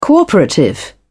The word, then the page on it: cooperative: British English